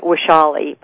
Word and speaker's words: Vaishali: V is in Vaishali. I know it sounds like it starts with a W. It's a Sanskrit name. It's actually spelled with a V.